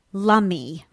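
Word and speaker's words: Lummi: I loved your prose on the lovely little island that is Lummy, right? Rhymes with tummy. – Rhymes with tummy, yeah.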